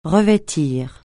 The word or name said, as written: revêtir